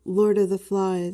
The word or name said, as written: lord of the flies